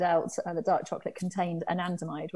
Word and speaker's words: anandamide: Was it andamide? Am I saying that right? – Anandamide. – Anandamide, okay, which is that bliss molecule.